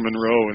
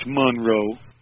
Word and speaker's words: Monroe: And by the way, if you hear me say Monroe, I try to say it like that because he personally corrected me once. – Okay. – I said Mr. Monroe, and he said, "It's Monroe."